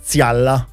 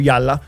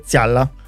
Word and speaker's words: Yalla: Foneticamente qua c'è scritto, si legge Zialla. – Ok. – Però c'è una J davanti, quindi per noi italiani magari noi diciamo Yalla, okay. – Però in greco sarebbe Zialla.